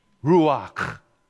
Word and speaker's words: ruach: The word in the Hebrew is ruach. Ruach. You got to have the CH. You got to go to... That's how you say it.